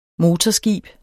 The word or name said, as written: motorskib